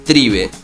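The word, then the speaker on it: Thrive: Hay un documental muy interesante que se llama, lo voy a deletrear, se escribe THRIBE, tribe, pero con H.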